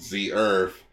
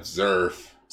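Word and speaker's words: Zearth: Yeah, apparently we've been pronouncing it wrong. It's called Z Earth instead of Zerf.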